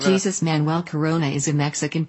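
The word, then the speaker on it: Jesús: And that's okay because that's what US English native speaker would expect, but obviously that's a Mexican footballer, so it's pronounced Jesus.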